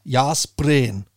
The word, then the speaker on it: Jászberény: Det staves J-A med accent S-Z-B-E-R-E med en accent grav N og så Y, men udtales Jászberény.